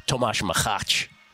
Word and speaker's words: Tomáš Machač: Yesterday, Tomáš Machač is actually how he pronounced it.